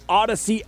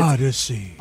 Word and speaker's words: Audacy: And, of course, you can find all of that on the Odyssey app as well. You can spell that with A-U-D-A-C-Y. – It's Odyssey. – Odyssey. Exactly.